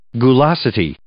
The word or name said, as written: gulosity